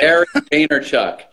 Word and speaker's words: Gary Vaynerchuk: Gary Vaynerchuk.